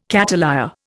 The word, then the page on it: cattleya: Indian